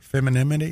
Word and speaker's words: femininity: That, did I say that right? Femininity is that right? Femininity. – Yeah, yeah.